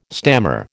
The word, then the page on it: stammer: American English